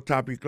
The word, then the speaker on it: topic: Alors, mot topic là, qui signifie sujet, ça veut dire accent tonique là tombé sous premier syllabe là.